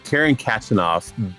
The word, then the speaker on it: Karen Khachanov: Karen Khachanov bests Nick Kyrgios in a five-setter at U.S.